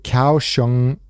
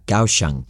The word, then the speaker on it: Kaohsiung: You nailed the pronunciation. That is exactly, I just checked, that is exactly how you pronounce it. It looks like it, but it is pronounced, yeah, Kaohsiung.